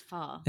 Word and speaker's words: pho: I mean, I say pho, but I guess it's pho. – It's pho. Yeah.